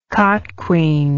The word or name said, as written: cotquean